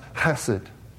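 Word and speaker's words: chesed (חסד): The phrase translated steadfast love comes from a very rich Hebrew term pronounced chesed.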